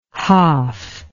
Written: haaf

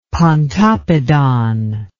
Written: pontoppidan

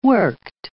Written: worked

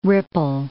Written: ripple

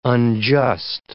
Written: unjust

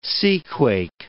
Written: seaquake

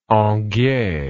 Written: Anguier

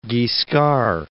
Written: Robert Guiscard

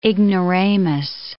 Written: ignoramus

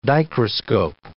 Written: dichroscope

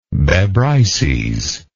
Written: Bebryces

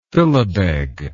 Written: philibeg

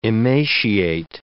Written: emaciate

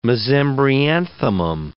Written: mesembryanthemum

